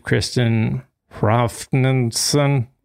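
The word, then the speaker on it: Kristinn Hrafnsson: WikiLeaks editor in chief Kristen Hrafnsson is exactly how that's pronounced. So in case you ever want to know how it's pronounced, just go back and listen to that.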